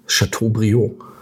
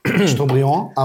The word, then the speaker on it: Chateaubriand: Chateaubriand. Ich hoffe, ich habe es richtig ausgesprochen. – Nicht ganz, aber toll. – Ja, ja. – Chateaubriand, aber...